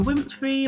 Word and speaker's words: Winfrey: So you have women like Oprah Winfrey.